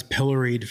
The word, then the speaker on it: pilloried: It's like, if I was pilloried for that, which rhymes with Hillary, by the way, pilloried, I think it's pilloried.